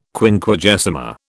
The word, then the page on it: Quinquagesima: British English